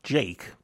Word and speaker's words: JVKE: This next artist is pronounced Jake, but he's actually spelt J-V-K-E, and he's big with the kids on TikTok.